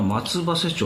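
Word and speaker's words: 松橋: 今、まあ、検察のという話でちょっと思い出したんですけども先日ですね、松葉瀬事件に再審が確定しまして、これはですね、あまりご存知ない方もいらっしゃると思うんですけども、熊本県の松葉瀬町、松に橋と書いて松葉瀬と呼ぶんですけども、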